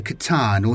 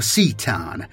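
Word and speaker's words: C'tan: Call had learned it was powered and controlled by the shards of the Catan or Cetan, depending on your pronunciation,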